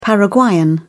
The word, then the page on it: paraguayan: British English